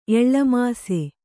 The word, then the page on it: ಎಳ್ಳಮಾಸೆ: Kannada